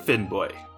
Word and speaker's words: Finnbogi: This ship was commanded by two brothers, Helgi and Finnboy. – Finnboy? – It is spelled Finnbogi, but I have been told that you're supposed to pronounce the G in that like a Y.